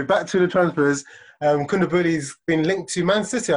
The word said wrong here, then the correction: Koulibaly: How do you say it? – Koulibaly. – Koulibaly, bro.